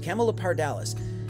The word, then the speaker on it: Camelopardalis: I keep saying this incorrectly, so I apologize. Camelopardalis. It's a giraffe, the constellation of a giraffe, but it comes from the words camel and leopard.